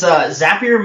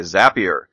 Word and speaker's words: Zapier: I am your host, and joining me today is, how do you pronounce it, Zapier? – It's, Zapier makes you happier is the trick. – Okay, Zapier.